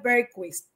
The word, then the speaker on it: Bergquist: Ciertamente mi apellido pues tiene muchas pronunciaciones. El correcto es Berquist, pero ya yo vivo con cualquier traducción o invento que hacemos.